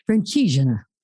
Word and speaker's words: Francigena: For the listeners who haven't read it, he takes the Via Francescana. Am I saying it right? – Francigena.